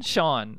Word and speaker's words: Sjón: She also met or Sean, Sean. That's how you pronounce it uh,